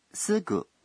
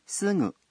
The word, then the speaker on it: すぐ: 这个词的发音是浊音すぐ，还是鼻浊音すぐ？ – 是鼻浊音すぐ。